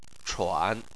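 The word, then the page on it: chuan3: tone 3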